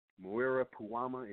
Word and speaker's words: Muira Puama: I'm going to say it again, nutmeg, cinnamon bark, Muira Puama. If you don't know how to spell that, that's M-U-I-R-A, Puama, P-U-A-M-A.